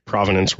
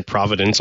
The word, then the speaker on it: provenance: I've heard it pronounced a bunch of different ways, including like a common misconception there. People say Providence a lot, which is, you know, a city in Rhode Island near where I live.